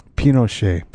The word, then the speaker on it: Pinochet: Pinochet. Did I say that right? – Pinochet.